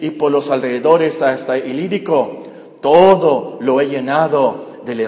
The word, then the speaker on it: Ilírico: Desde Jerusalén, la parte más al sur donde ministró, hasta el Ilírico, así se pronuncia, es más o menos donde está ahora Yugoslavia o el área de Ucrania.